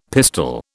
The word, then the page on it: pistol: British English